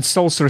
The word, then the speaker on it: Solcer: And Solser is spelled C-O-L-C-E-R, isn't it? – That's correct.